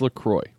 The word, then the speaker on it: LaCroix: Lechol. – No, say it. – Lechol? – No, say it right. – Lecha. – No, say it right. – It's LaCroix.